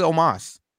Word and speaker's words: Omos: Please stop calling him Omos. Please. He pronounces it Omos.